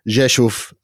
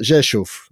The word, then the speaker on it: Rzeszów: I've been to Rzeszów, as it's pronounced.